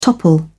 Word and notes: topple: British English

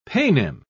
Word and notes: paynim: British English

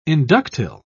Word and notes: inductile: British English

